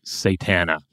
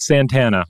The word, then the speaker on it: Satana: Well, let's talk about Tura Satana. Not Santana, as I sometimes keep mispronouncing it.